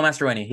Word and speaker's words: Mastroeni: Mastroeni? – Yep, Pablo Mastroeni, yep.